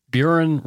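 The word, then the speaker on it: Burin: Anyway, Buron rhymes with urine.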